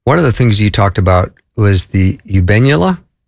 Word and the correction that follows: habenula: Did yes. – I say that right? – Eubenula, yes. – Abenula? – Habenula. – Habenula.